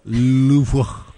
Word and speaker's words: Louvre: Will you please pronounce that correctly? – Is it Louvre? – Louvre. – Yeah, there you go. – Louvre. I did. I talked. Thank you for listening. I did talk about that. It's one of the most mispronounced words. It's Louvre. I just feel silly saying that with my American accent.